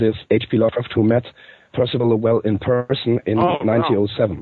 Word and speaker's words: Percival Lowell: I'm not sure if I pronounce Percival Lowell, yeah.